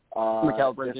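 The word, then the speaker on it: Mikal Bridges: I mean, let me put it this way: if you're having a player like McCall Bridges, I think is how you say it, Michael McCall Bridges. – McCall Bridges,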